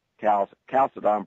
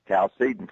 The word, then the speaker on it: Chalcedon: The reason we pronounce that way, however, is because that's easier for a Southerner to say Chalcedon Presbyterian Church than Chalcedon Presbyterian Church, flows more smoothly off a Southerner's tongue.